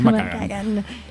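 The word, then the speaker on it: McKagan: sí, tranqui, se dice así, es McCagan.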